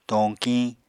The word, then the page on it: toñkiñ: Apatani